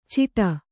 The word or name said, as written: ಚಿಟ